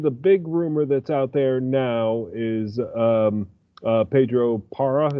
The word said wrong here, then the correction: Porro: or is it Parra or Pora, is it pronounced? You guys know me and my pronunciation, but Porro, isn't it? – Yeah, Porro. Okay, there we go.